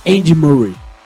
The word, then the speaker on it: Andy Murray: Meu fino da bola vai para a jogada do tenista Andy Murray. – Boa pronúncia, hein, mano? Não, gostei! – De novo, vai! – Andy Murray.